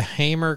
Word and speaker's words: Hamer: How do you say that? Hamer? – Yeah, it's a Hamer Carina Explorer.